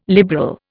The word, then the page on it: liberal: Indian